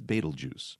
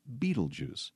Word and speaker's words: Betelgeuse: The reddish-orange beacon is Betelgeuse, sometimes pronounced Betelgeuse.